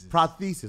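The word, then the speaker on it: prosthesis: parathesis. – Parathesis. – Did I say it right? – Prothesis.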